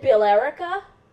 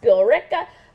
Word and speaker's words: Billerica: Well, when they were talking about it on the news, they called it Billerica, Massachusetts. – Oh, that is how it's spelled. – It's how Billerica. It's spelled, but it's Billerica. So I actually like wrote them in. I'm like, you dudes don't know what you're talking about. It's pronounced Billerica.